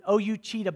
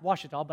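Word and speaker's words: Ouachita: So I go back home from this FCA conference, and I open my mailbox, and there's a letter from O-U-A-C-H-I-T-A. I pronounced it O-U Cheetah Baptist University. It's pronounced Washita, but I thought O-U Cheetah sounded pretty good, okay?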